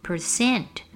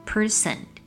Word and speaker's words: percent: 另外，百分号 percent 这个字也要留意，常常会听到有人把重音放在第一节，念成 person，很容易跟一个人 person 这个字混在一起。其实正确的读法是重音要放在第二节，percent percent。